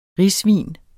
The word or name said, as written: risvin